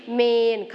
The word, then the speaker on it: เมรุ: บางคนอาจจะอ่านว่าเมรุหรือเมรุใช่ไหมคะแต่จริงๆคำนี้ต้องอ่านว่าเมน